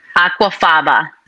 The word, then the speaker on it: Aquafaba: Aquafaba.